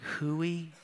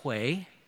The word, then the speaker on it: Hui: And Ellie, who was 7, she goes and grabs our little whiteboard, and she grabs a marker, and she writes 3 letters, H-U-I. And we're like, hooey? And she's like, it's pronounced Huey.